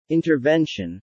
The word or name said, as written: intervention